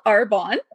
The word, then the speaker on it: Arbonne: It's called Arbonne.